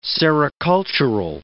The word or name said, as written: sericultural